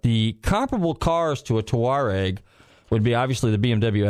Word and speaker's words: Touareg: T-O-U-A-R-E-G.